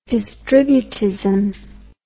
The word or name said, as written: distributist